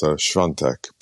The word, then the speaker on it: Świątek: And the pronunciation in Polish is Świątek, but we won't go into that.